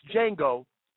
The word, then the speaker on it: Django: If you saw a movie where the character clearly says in the movie and in the trailer, folks, it's Django, the D is silent.